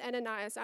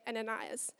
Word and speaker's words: Ananias: Now, I'm going to pronounce it Ananias. I've got the mic, and I'm going to say Ananias. It is Ananias.